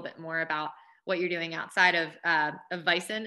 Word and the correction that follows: Vivens: Is that how you pronounce the company? – Vivens.